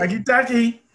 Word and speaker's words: Takitaki: I believe this guy's name is Siani Takitaki. Takitaki, yes, that's what I said. – Takitaki.